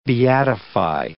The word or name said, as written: beatify